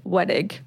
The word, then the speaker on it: Wedig: I guess like you guys probably, we never say it, but how you say it is Wedig. People like do like wedding, wedage. Like I get all kinds of stuff, but it's just how it looks. Wedig.